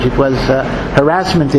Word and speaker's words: harassment: which for some reason over the past decade is now pronounced harassment. I don't know why, that's not the pronunciation of the word. I don't know who made that change. It was harassment is the word, but now it's pronounced harassment.